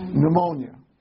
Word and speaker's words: pneumonia: The word, when you have trouble with your lungs and it fills up with fluid, we call that, starts with a P, but the P is silent. Pneumonia.